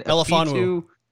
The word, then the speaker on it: Melifonwu: Fitu – how do you say it again? – Melifonwu.